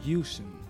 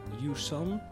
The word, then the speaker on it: Hewson: Paul Hewson. Hewson. – Oké. E okay. – H-E-W-S-O-N.